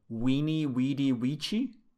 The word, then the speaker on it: veni, vidi, vici: So it's V-I-N-I, and then V-I-D-I, and V-I-C-I, or whatever they are. Veni, vidi, vici, right? And apparently, it's supposed to be pronounced actually weeny, weedy, weechy, which sounds way worse. – Weeny, weedy, weechy? – Yeah, because I guess the V is actually a U sound, right?